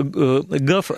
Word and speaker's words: gov: Конечно же, произносится ГАФ, а пишется ГОФ, да? – ГОФ, да.